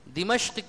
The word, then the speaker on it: دمشق: تو عربی میں اس کا تلفظ دمشق یا دمشق یا دمشق، یہ تینوں اس کے تلفظ ہیں.